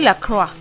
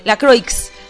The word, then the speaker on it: La Croix: Ven, se pronuncia la Croix. Pues sí, pero mucha gente no lo pronuncia como se escribe, así que se escribe la Croix. La Croix.